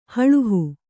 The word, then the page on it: ಹೊಲ್ಲಹ: hollaha